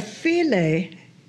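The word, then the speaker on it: filé: You didn't have filet in okra gumbo. When you had filet, you had filet gumbo. And that's F-I-L-E with the accent on the E, else it would be foul.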